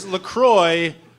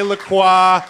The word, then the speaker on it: LaCroix: It is LaCroix rhymes with enjoy. Simple as that. It's over. There's no more debate about it. If you ever hear somebody say LaCroix, yep.